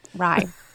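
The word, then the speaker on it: Rai: I know somebody corrected me on how to say this, but Ray, which I actually did enjoy. Rye. – Yes. Like the bread.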